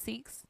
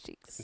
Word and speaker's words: Sikhs: Sorry, it's Sikhs, not Sheiks.